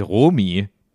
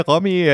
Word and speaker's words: Romy: R-O-M-Y? – R-O-M-Y. – Das ist doch dann aber die Romy. – Nee, es wird Romy ausgesprochen. – Okay, ich würde es Romy nennen, um ehrlich zu sein. – Ja, ich auch, aber es wird Romy ausgesprochen, ja.